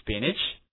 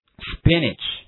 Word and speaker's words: spinach: 好，跟我读一下这个词 spinach spinach，注意一下这个 NACH 这个 A 的发音读短音的 A 叫 spinach， right？